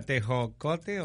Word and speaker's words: tejocote: Vamos al tecojo. – ¿Sería un lugar o? – No, oye, yo no queriéndolo disimular y sí, está peor. Está peor. Este tejocote, ¿no? – A ver, ¿cómo se dice? Tejocote.